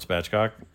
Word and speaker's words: Spatchcock: Man, this is Lord Spatchcock. I heard you say my name. Is that spelled right? S-P-A-T-C-H? – Yes.